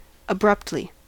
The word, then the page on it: abruptly: American English